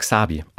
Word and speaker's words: Xabi: Die heißt Xabi, also Spanisch, einfach nur deutsch ausgesprochen, X-A-B-I geschrieben. Ein Mädchen, mittlerweile 8 Jahre alt.